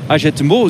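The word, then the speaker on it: Hagetmau: Na de start in Agetmo, zo moet je het precies uitspreken.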